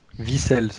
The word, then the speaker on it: Vicel: Vicel, ça se prononce, j'avais déjà vu ça.